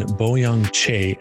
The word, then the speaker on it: Boyoung Chae: I have a very special guest with me today from the state of Washington, Boyoung Chae.